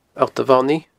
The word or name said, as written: alltamhonaidh